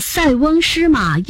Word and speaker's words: 塞翁失马: 那为什么我们都读作塞翁失马焉知非福呢？既不读塞也不读色。 – 因为这个字读作塞是另一个意思，指可以做屏障的险要地方。